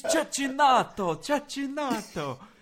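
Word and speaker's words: Cecchinato: Yeah, well, look, I it's just because there's a funny Twitter video of him, of someone like trying to teach John McEnroe how to pronounce it because he kept doing it wrong on the broadcast. And he's like, it's Cecinato, Cecinato.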